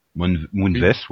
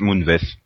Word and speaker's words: Moonves: Oui, non, mais, puisqu'on parle de vieux, il y a celui de CBS, euh, donc euh, Lee Moonves, ou je sais pas comment ça se prononce, hein. Moonves, ouais. – S Moonves. Ça rime comme ça.